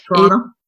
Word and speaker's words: Toronto: Is that how they say it? Toronto. – Toronto. Yeah.